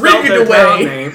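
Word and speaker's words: Ridgway: Dude, so we've got Ridgeway town that's spelled R-I-D-G. – W-A-Y. – There's no E. It's just Rigged-Away.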